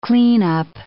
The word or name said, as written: cleanup